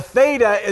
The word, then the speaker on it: theta: And a theta is pronounced th.